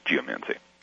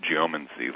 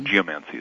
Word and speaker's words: geomancy: The way that I was taught to pronounce it was geomancy. – Oh. – Emphasis on the first syllable. I know people who pronounce it geomancy, like, you know, geometry, geology, something like that.